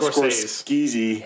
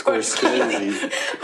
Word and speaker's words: Scorsese: S-C-O-R-S-C-S-C-E-S-E. – Let's spell it out. – Scorsese. It looks like it's spelled Scorsese. – Scorsese. – But it's Scorsese.